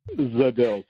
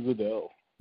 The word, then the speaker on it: Zajdel: Zajdel. So Ben Zajdel.